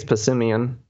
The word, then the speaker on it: Passimian: The teamwork Pokemon, how do you say it? Pass? – I think it's Passimian.